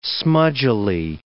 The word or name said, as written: smudgily